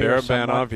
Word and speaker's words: Barabanov: Is that, am I pronouncing his name correctly? – Barabanov, yep.